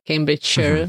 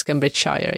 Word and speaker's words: Cambridgeshire: Yeah, I know people are confused about the Shire and Shire, like Cambridgeshire versus Cambridgeshire. You don't say Cambridgeshire. You're not in a hobbit land. Shire, like Cambridgeshire.